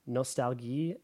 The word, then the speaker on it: Nostalgie: So the German word for nostalgia is Nostalgie. It's actually spelled the same as English, but with an E at the end instead of an A. – Nostalgie. – Yeah, and then Ost, O-S-T, is the German word for East.